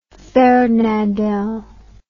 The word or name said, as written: Fernandel